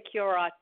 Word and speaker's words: Curott: Oh, either one. Well, generally we say Curott, but we're not even sure how it's pronounced.